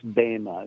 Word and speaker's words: bema: Now it's spelled B-E-M-A, but there's two E's in Greek, and so it's this the eta. Sorry, it's a long story, but it's always long, so it's bema. It's pronounced bema, even though you would look at it in English and say, "When I say bema, a bema seat."